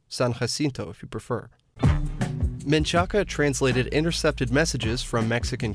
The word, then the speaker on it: Menchaca: That pronunciation is supposedly an anglicization of a Spanish surname, Menchaca.